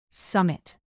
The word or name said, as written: summit